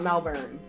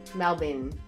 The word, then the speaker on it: Melbourne: Not Melbourne. Melbourne. That's how they say it.